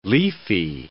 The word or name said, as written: leafy